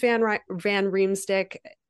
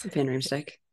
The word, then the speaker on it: Van Riemsdyk: James Van Riemsdyk. Yes, I said that right. Is Van Riemsdyk.